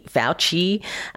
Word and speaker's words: Fauci: On that list, Anthony Fauci, F-O-W-C-H-E-E, which is an issue.